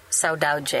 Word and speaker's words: saudade: So a question for Bio and Giordi is, I've heard you two use this term now a couple times of saudade. Am I pronouncing that correctly? Mm-hmm.